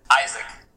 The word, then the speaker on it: Isak: Well, if you were in Russia, you would definitely say it right. But since we're here in the U.S., I haven't been called that. The only person who calls me that is my mother. – So. Well, what should I call you? – Isaac. – Isaac, okay. – I know, the way it's spelled that interesting in Russia, they missed one A, so now I'm Isak. But no, my friends call me Isaac, so.